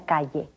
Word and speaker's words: calle: Y siga directo por esa calle. – The double L is pronounced like the Y in yes. Repeat.